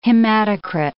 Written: hematocrit

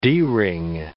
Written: D ring

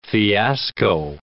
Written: fiasco

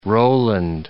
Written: Rowland

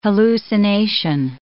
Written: hallucination

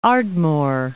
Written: Ardmore

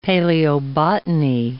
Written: paleobotany

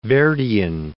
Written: Verdian

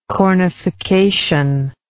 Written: cornification

